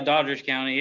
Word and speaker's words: Doddridge County: Yeah, Doddridge County, yeah.